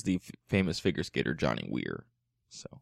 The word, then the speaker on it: Weir: There is a physician named Silas Weir Mitchell. Would you pronounce W-E-I-R? Is that Weir?